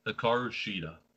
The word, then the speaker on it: Hikaru Shida: Hikaru Shida.